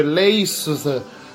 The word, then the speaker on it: Leicester: Cioè con la R alla fine, poi in realtà diventa, è abbreviato perché si scrive Leicester, però si legge Leicester, Leicester, cioè una roba così che non però era, era, era stato, stato, un momento meraviglioso.